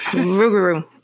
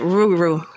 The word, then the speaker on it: Rougarou: You're like pronouncing like all the letters. You can't pronounce all the letters. You gotta drop off some of the letters. – But it's the Rougarou, right? – Rougarou.